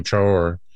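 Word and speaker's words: Traoré: And I think, yes, Ibrahim, and I believe he pronounced his last name Traore.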